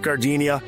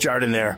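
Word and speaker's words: giardiniera: And guys, when you're at the counter, don't call it Gardenia. It's pronounced Jardiniere.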